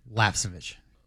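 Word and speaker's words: Lapcevich: Heim gets into a driver by the name of Trayton Lapasevich. It's easy for me to say. – Lapasevich. That's how it's the name pronounced.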